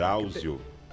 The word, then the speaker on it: Drauzio: Foi na padaria, descobri que o doutor, doutor Braulio, Braulio Vazela, ele falou que. – Varela. Tem... Drauzio. – Isso, Braulio Varela.